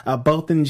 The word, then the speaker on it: Botham: It's Botham. – Thank you. – Thanks. A lot of emotions this week during the trial with Amber, the officer that shot and killed Botham Jean while he was at his home sitting on his couch.